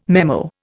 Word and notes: memo: Indian

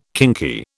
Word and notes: kinky: British English

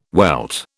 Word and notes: welt: British English

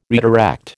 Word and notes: redirect: American English

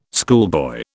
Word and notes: schoolboy: British English

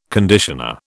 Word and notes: conditioner: British English

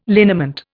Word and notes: liniment: Indian

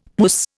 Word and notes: puss: Indian

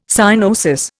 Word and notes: cyanosis: Indian

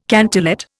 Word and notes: cantillate: Indian

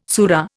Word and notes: sura: Indian

